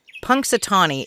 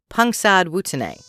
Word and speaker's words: Punxsutawney: Today, all of us with our various derivatives of Philly and Pennsylvania accents say Punxsutawney, but Native Americans pronounced it Punxsutawney, that literally translates to mosquito town.